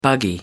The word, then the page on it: buggy: American English